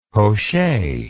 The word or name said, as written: poche